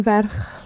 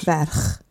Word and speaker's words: Ferch: I got Branwen Ferch Llyr. – Is that how you say that word? – Yeah. – The middle word? – Ferch.